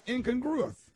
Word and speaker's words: incongruous: You said it fast and you said it wrong. – Yeah, you said incongruous. – The word of the week is incongruous.